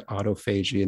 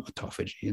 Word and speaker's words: autophagy: So it gets shortened to autophagy, and then you get some people who put their fancy little spin on it and it's pronounced for them autophagy. And there you go. That's why autophagy and autophagy are both correct.